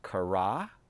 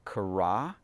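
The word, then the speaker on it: kara (קָרָא): Our, he- our ancient Hebrew word for today is pronounced kara, kara, and it means to call.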